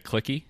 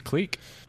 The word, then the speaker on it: clique: You say cliquey? – Would you rather I say clicky? Clown? – Yes. – Nah. – Cliquey? – Cleek. You never heard that word pronounced clique? – No. – Well, now you have.